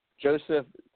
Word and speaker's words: Jozef: Joseph Narodowski, but it's spelled J-O-Z-E-F. – Yeah, it's Joseph Narodowski. – Oh, it's Joseph translated.